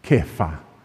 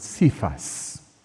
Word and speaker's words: Kepha: And there's only one term for rock in Aramaic, Kepha, or as some pronounce it, Cephas.